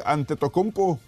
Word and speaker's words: Antetokounmpo: Perdieron ante Milwaukee 104-99 la noche de anoche con 19 puntos de Antetokounmpo Lo dije bien, güey, la primera.